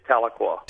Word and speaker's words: Tahlequah: Is it Tahlequah in Oklahoma? Am I pronouncing that correctly? – It's Tahlequah.